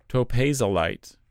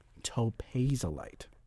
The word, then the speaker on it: topazolite: Topazolite. – Topazolite.